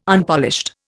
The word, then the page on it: Unpolished: Indian